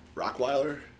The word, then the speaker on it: Rockweiler: We're here with Benny, and it's Rockweiler. – That is correct, sir. – Right? – Pronounced like the dog, only with rock. – Rockweiler. – Instead of rot.